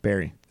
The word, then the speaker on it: Barrie: Unless I'm mispronouncing it, it's Barry, B-A-R-R-I-E, Barry.